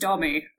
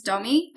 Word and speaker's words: Dommi: And my name is Dommi. Okay, so my name's Dommi. My full name is Dommarine, but that's just far too much for some people to pronounce. So it's just Dommi.